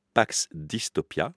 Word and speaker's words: Pax Dystopia: On va parler d'un ouvrage qui s'appelle Pax Dystopia, je le prononce correctement. – C'est juste, oui.